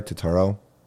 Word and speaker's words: Turturro: Did I say that right, Turturro? Yeah.